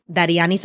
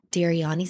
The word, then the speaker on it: Dariany: Anyway, if you'd like to follow me on Instagram, it's Dariany Santana, Dariany Santana. It's spelled like Daria, New York, but it's not. So don't call me.